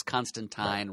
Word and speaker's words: Constantine: Alan Moore went out of his way to tell us that it's Constantine rhymes with sign.